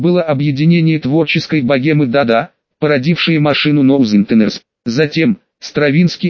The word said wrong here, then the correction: Стравинский: и Кандинский, простите, это произносится как Стравинский и Кандинский.